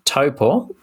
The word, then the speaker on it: Taupo: But, she did also say, I ran at Taupo, as I understand the pronunciation is, parkrun today, and took my P index to 5.